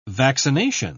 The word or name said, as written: vaccination